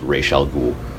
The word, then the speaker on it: Ra's al Ghul: I'm confident that it's supposed to be pronounced Ra's al Ghul. – It is.